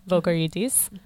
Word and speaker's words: Vogorides: but Stefanos Vogorides. – Vogorides.